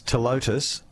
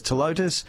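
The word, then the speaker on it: Ptilotus: A couple of text messages about, and I'm going to pronounce this telotus, even though it starts with a P, because I don't know, okay? – Yes.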